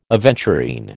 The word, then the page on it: aventurine: American English